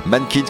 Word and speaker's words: MNNQNS: Mannkins qui s'écrit M-N-N-Q-N-S, ils sont de Rouen.